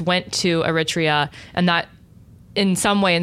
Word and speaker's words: Eritrea: Eritrea. – Eritrea.